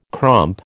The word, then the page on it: crump: American English